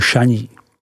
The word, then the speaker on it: Chagny: Vína se pod označením Rulí smí vyrábět ve dvou vinařských obcích, a to Rulí a Chagny, vyslovuj Šaní.